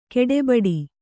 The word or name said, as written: ಗಡಬಡ